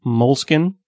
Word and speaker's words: Moleskine: It's spelled Moleskine, M-O-L-E-S-K-I-N-E, but it's pronounced Moleskine, I believe.